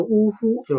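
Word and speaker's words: 於戲: 呜呼，呜呼，这两个字呢，在这儿写的呢，叫写的写成叫于戏啊，于就于是的于，戏呢就是。演戏的戏，但这两个“ 鱼戏” 这两个字呢，读的时候呢，要读成“ 呜呼”，就是感叹词。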